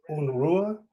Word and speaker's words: Unruhe: I have Cyril Schäublin, who is the director of the new film called Unrest or Unruhe. Do I have that pronunciation right? – Yeah, that's right. That's right.